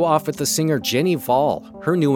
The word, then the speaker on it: Hval: This is Ginny Vol, and that last name is spelled H-V-A-L. Ginny Vol, she's a singer from Norway.